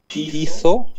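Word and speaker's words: Pitho (πείθω): Pitho, pierwsze słowo, a drugie hipiko.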